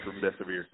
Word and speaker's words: remdesivir: Remdesivir, your death is near. That's how you pronounce it.